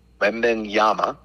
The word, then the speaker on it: Wembanyama: The presumptive overall pick, Victor Wembanyama, and I probably butchered that.